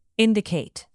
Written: indicate

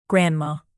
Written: grandma

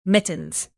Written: mittens

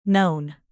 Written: known

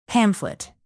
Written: pamphlet

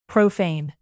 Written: profane

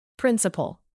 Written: principle

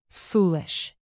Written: foolish

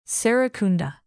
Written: serrekunda

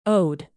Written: ode

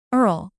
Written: earl

